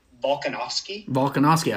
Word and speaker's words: Volkanovski: Is it Volkanovski? – I think you got it right.